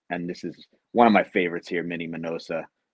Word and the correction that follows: Minnie Minoso: Minnie Minoso i always pronounce his name wrong so if you hear me say minosa just you know correct me in your head and uh and then you know another one of my uh, uh collecting favorites here uh we oh, yeah, have Jethroe.